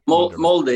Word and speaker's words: Molde: Is it Molde, by the way? – Molde, yeah. I played for my local team, Klausningen, until I was 22.